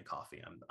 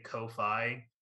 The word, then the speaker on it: Ko-fi: I think it's pronounced coffee, but I always say Ko-Fi so that people like spell it correctly.